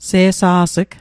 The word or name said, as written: se'sa's'g